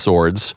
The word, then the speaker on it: Swords: Sords. I guess that would be pronounced Swords. – Sounds right.